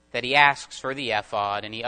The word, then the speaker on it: ephod: We reviewed several things that happened and pointed out the role of the priestly ephod that was used to inquire of the Lord. I'm going to pronounce it that way.